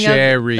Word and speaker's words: Sheary: Sherry. – That's right. Connor Sheary of the Penguins. – Even though it's spelled Sheary.